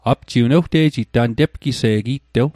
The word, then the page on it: tepgise'g: Mi'gmaq